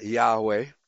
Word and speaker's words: Yahweh: I mean, all the evidence tells us that the original pronunciation was Yahweh.